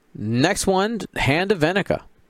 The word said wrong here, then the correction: Vecna: You did it again. Hand of Vecna. – Ah, dang it, I did it again! Ah, adding extra syllables.